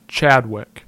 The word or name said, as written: Chadwick